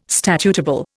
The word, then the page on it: statutable: Indian